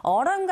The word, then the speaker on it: autumn: autumn 같은 경우에는 autumn 하셔도 되고 영국식 발음이겠죠. 북미권에서는 그래도 t 사운드를 조금 네 약하게 발음하는 경우가 많은지라 autumn 이렇게 들리게 되고 발음하시면 됩니다.